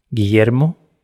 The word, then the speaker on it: Guillermo: José Guillermo Guzzoni Cox.